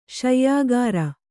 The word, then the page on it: ಶಯ್ಯಾಗಾರ: śayyāgara